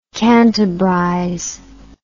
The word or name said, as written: cantabrize